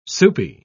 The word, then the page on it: soupy: British English